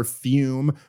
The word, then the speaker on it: Fum: I'm talking about our longtime sponsor, Fume, pronounced Fume, but it's spelled F-U-M.